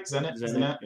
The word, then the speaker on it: Zenit: Zenit.